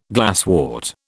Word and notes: glasswort: British English

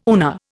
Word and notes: UNA: Indian

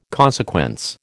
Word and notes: consequence: American English

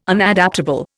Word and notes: Unadaptable: Indian

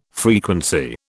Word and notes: frequency: British English

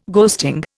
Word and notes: ghosting: Indian

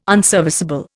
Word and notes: unserviceable: Indian

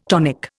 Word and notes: tonic: Indian

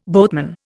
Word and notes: boatman: Indian

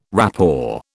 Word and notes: rapport: British English

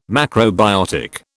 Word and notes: macrobiotic: British English

